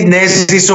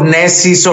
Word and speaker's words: Nezis: Basically, it's Ne. Ne? It's okay if you say Nezis or Nezis or Nezis, but I want it to be like ne, not ni, not na.